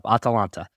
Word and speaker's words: Atalanta: Atalanta.